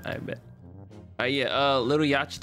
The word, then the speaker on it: Yachty: No, that's not how you say his name. – Say Yachty.